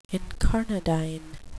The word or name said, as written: incarnadine